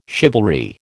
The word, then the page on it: chivalry: American English